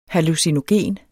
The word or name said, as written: hallucinogen